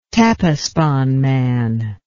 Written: Tepexpan man

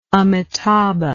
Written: amitabha

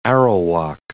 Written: Arawak

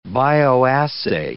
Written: bioassay

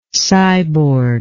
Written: cyborg

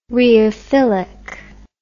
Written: rheophilic

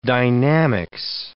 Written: dynamics

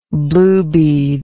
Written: bluebead

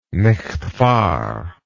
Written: nicht wahr

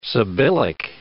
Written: sibyllic

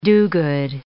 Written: do-good

